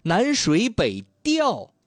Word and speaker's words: 调: 以缤纷的色彩绘就美好画卷，南水北调，这个和调色盘的调是一个字哦，只不过是它是多音字嘛。